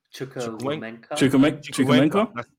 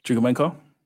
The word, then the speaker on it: Chukwuemeka: Chukwuemeka. – Sorry, say again? – Chukwuemeka.